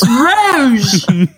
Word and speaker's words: Rouge: And put him right at Rogue. I don't care Rouge. If it's spelled right. – It's Rouge. – It's a name. You have to read it properly.